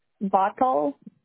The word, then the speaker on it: Bothell: Mercer, M-E-R-C-E-R. – Okay. – Then I'll do a Linwood. – Okay. – Bottle. – How do you spell that? – B-O-T-H-E-L-L.